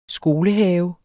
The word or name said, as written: skolehave